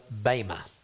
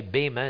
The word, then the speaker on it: Bema: That's because the word spelled with an eta and not an epsilon. Some people say Bema, it's not, it's an eta. It's just like it sounds.